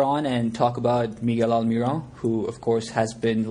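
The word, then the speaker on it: Almirón: It's Almiron, so the sort of stress is on that final syllable. Almiron.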